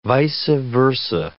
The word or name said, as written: vice versa